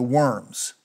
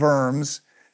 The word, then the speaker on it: Worms: The Diet of, it's spelled Worms. You're supposed to say it with the V, the Diet of Worms.